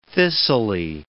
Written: thistly

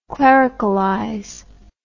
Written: clericalize

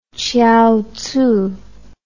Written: chiaotzu

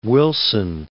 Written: Wilson

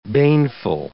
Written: baneful

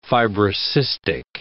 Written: fibrocystic

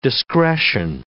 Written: discretion

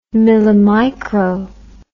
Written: millimicro

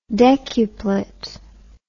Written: decuplet

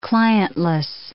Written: clientless

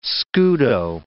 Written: scudo

